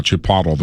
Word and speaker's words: Chipotle: So Chipotle. That's how you say it, right, Bill? – That's correct. – Yeah, Chipotle, the restaurant.